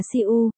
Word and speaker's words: Thập Ngũ: Thập Ngũ phát âm là siu, đọc nhanh thì nghe giống Thư phát âm là su.